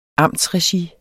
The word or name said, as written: amtsregi